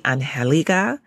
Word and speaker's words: Angelica: When people say my name correctly, when they say Angelica and they're using it with a Spanish accent, oh, it is just the sweetest thing ever.